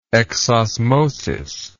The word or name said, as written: exosmotically